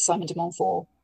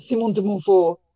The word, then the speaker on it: Simon de Montfort: So Simon de Montfort hailed from France. He spoke French. He would have pronounced it Simon de Montfort or Simon de Montfort if you're going to be precise.